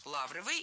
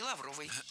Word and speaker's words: лавровый: Но если кому-то кажется такой вариант странным, и если вы привыкли говорить лавровый, то переучиваться не надо, ведь норма ударения в этом слове двойная – лавровый и лавровый.